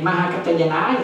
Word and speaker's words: Mahakacchayana: Sư đề Mahakacchayana rồi, quý tử nhớ nha, chữ C này á luôn luôn là quý tử phát âm là chữ C H, nên có mấy cô đọc cái chữ K K đó là xin lỗi, chữ C H chứ phải K.